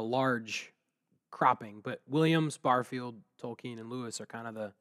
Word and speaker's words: Tolkien: Early on, Lewis's closest friendship was with Tolkien, and I pronounce it that way because I believe it's the way he pronounced it.